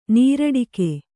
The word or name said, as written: ನೀರಡಕೆ